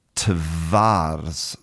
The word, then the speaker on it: twarz: And in Polish, the word for face is spelt T-W-A-R-Z. And the correct pronunciation should be tvars.